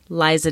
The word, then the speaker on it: Liza: So last but not least, I played Stone Harbor by Lisa Daly. Liza Daly, probably, while we're mispronouncing everyone's name.